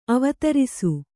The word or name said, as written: ಅವಧಾರಿಸು